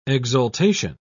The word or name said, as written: exaltation